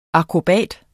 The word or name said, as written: akrobat